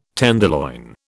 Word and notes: tenderloin: British English

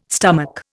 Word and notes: stomach: Indian

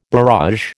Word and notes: barrage: American English

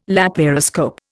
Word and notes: laparoscope: Indian

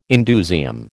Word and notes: indusium: American English

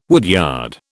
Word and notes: woodyard: British English

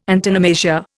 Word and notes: antonomasia: Indian

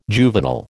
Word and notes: juvenile: American English

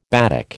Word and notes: batik: American English

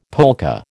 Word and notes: polka: American English

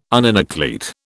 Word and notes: uninucleate: British English

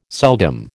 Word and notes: seldom: American English

